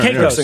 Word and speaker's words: Caicos: Sure, Caicos.